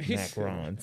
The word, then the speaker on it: macarons: She sells macaroons. Is that how you say it? – Macarons. – Macarons, sorry.